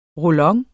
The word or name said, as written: roll-on